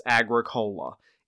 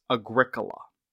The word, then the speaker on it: Agricola: You look at this name, and it looks like it's pronounced Agricola.